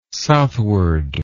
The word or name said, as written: southward